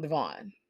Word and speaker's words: Devoin: So on Teen Mom 2, Brianna's father of her daughter, Nova, is his name is Devoin, and you can see it's spelled kind of like Devoin, but people call him Devon.